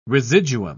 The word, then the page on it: residuum: British English